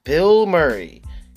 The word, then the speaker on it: Bilmuri: His name is B-I-L M-U-R-I. That is Bill Murray. B-I-L-M-U-R-I.